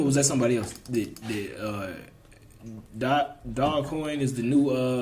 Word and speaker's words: Dogecoin: A couple days later, hey man, this apparently it's pronounced Dogecoin. – Okay, yeah, that makes sense. The E, yeah, Dogecoin.